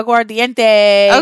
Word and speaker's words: Aguardiente: And they gave them, oh goodness, I'm going to butcher this, Aguardiente, A-G-U-A-R, Aguardiente. – Diente, yeah, Aguardiente.